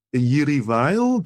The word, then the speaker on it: Jiří Weil: Mendelssohn is on the Roof by Jiří Weil.